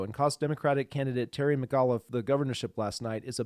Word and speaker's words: McAuliffe: And then how do you say Terry's last name? – McAuliffe. – Is it McAuliffe? Okay, yeah. McAuliffe.